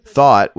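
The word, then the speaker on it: thot: Thought, which rhymes with not, started life as an acronym for terms like that hoe over there.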